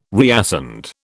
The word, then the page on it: reascend: British English